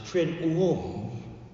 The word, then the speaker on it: Triduum: And so we went through it, and it's T-R-I-D-U-U-M. So one way to pronounce it is Triduum, which is just not right. I've always pronounced it Triduum. And if I say it quick and with conviction, most people don't know that I may be pronouncing it wrong.